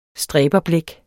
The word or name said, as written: stræberblæk